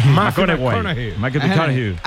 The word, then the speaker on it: McConaughey: Mateo McConaughey. – Sí. Sí. – Que hay que ir corrigiendo la pronunciación. – McConaughey.